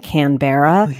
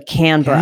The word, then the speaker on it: Canberra: It reminds me of when I spent time in Australia, the capital of Australia, Americans pronounce it Canberra, but it's actually Canberra. – Canberra. – That's how Australians pronounce it.